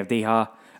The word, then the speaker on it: Avdija: I always pronounce it wrong, but I'm pronouncing it correctly this time. Danny Avdija.